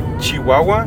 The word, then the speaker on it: Chihuahua: Because I come from Chihuahua, and in Chihuahua, it's known that we pronounce the CH sh. So other people say Chihuahua, and yeah. We say Chihuahua.